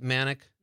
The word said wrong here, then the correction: Monick: Monick. I thought so. Rachel Monick. Rhymes with Bionic.